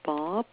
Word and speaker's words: Bobb: and the last name is Bob, B-O-B-B.